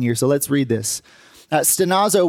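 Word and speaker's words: stenazo (στενάζω): And the Greek word for groaning is pronounced stenazo.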